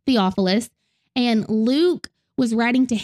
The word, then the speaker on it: Theophilus: And he was actually writing to one man named Theophilus. And I did say that correctly because, yes, I Googled to make sure I said that name correctly.